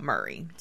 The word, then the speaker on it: Maury: So if you take the E off, then it comes out Murray. So that's probably the gentrified version of the name, but we believe it is how Abram Maury pronounced his name.